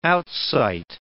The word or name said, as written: outsight